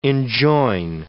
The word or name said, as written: enjoin